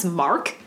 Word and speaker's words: Marque: Um, on this night, we have 10 year old Krystal Surles and her 7 year old sister, I think her name is pronounced Mark, it's M A R Q U E. Marque, Mark.